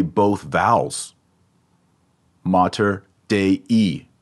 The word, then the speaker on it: Mater: Notice that that first A is not short, it's long because the accent goes on the second to last syllable. You'll get tired of me saying this, but you gotta know it. It's not matter, Mater Dei. No, it's Mater Dei.